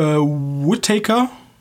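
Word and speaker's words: Whitaker: Und ähm, weiteres Schwergewicht ist äh, Forrest äh, Whittaker.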